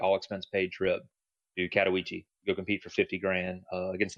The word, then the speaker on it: Katowice: We participated in IEM qualifiers for Katowice. Jenny helped me out with that pronunciation. I thought it was Katowice, but, you know, I'm just a Southern public school educated kid.